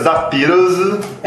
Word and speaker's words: The Pillows: a pronúncia japonesa é Zapiros.